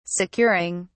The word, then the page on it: securing: British English